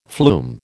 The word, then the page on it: flume: American English